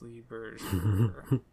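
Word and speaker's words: Lee Bergere: How to pronounce Lee Berger. French pronunciation is at the top of the list. Lee Berger. – Oh, okay. – The English version says Lee Berger.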